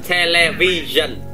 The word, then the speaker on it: television: Television. – Television. – Chữ này chưa phát âm rõ. Cứ hai chữ đầu là tele, tele. Television.